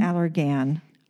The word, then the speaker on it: Allergan: I think that because they call it Allergan, or maybe they think it's allergen. It looks like it's spelled like allergen. So they just emphasize Allergan. It's Allergan.